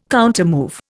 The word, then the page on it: countermove: Indian